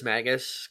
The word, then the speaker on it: Magus: Yes, I think Magus is how you spell the word Magus, but I don't know how it's pronounced. – I've listened. Everybody else says Magus because I've listened to a bunch of different.